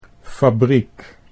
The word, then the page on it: fabriek: Dutch